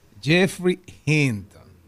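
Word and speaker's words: Geoffrey Hinton: Jeffrey Hinton.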